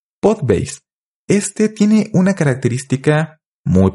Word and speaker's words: Podbase: Este sitio es Podbase, así se pronuncia, pero se escribe POD ba.se.